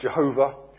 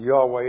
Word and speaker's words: Yahweh: Older um, scholars pronounce it Jehovah, um, modern scholars tend to pronounce it Yahweh.